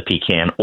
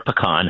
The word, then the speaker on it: pecan: Oh, you have to go with a pecan or pecan, however you pronounce it. It's good both ways.